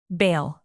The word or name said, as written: bale